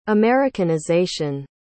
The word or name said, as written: americanization